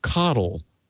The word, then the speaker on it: CoDel: Uh, it's the name is C O D E L, and it's pronounced coddle, unfortunately.